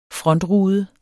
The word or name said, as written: frontrude